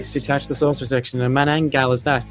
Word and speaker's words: Manananggal: At number 8, we have Manananggal. No. Manananggal. What? There you go. Pronounce it properly, wow.